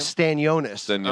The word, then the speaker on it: Stanionis: It was Aimantas Stanionis. Is that how you say his name? – Stanionis. I'm sorry that I was butchering it at times, even though he's my Lithuanian brethren.